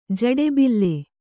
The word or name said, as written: ಜಡೆಬಿಲ್ಲೆ